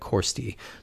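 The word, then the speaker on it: Ciorstaidh: It's C I O R S T A I D H, and it's pronounced like Corsti.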